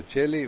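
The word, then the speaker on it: Caeli: Bueno, pero Cheli, vaya, en italiano la C sola se pronuncia así, sí, pero para que sea como K, sea doble C, ¿verdad? – Y dicen también, ¿sabes?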